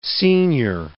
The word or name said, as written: senior